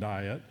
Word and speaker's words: Diet: January 28, 1521, no, the Diet of Worms was not the latest protein diet. A diet, it's actually pronounced diet. That's an assembly.